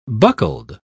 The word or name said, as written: buckled